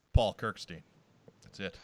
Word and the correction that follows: Kirstein: How is it actually pronounced? – Kirstein. – Oh, so it's not even that bad. I was thinking I was just way off, but it's just the Steen-Stein thing. Okay.